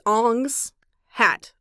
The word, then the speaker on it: Ong's Hat: It's spelled O-N-G-S, Ong's Hat, as in hat belonging to Ong.